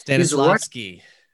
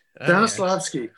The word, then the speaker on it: Stanislavski: And I got to pronounce this right. Konstantin Stanislavski, or Stanislavski, whatever. – Stanislavski. – Stanislavski. Yeah.